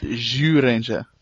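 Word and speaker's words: Zyuranger: Because Gokai Silver's ultimate power, Gokai Silver's existence in himself is basically combining the ultimate powers of Jew Ranger, Abba Ranger, and Time Ranger. – Oh man, you said Jew Ranger. – That's how it's pronounced. – It's Jew Ranger.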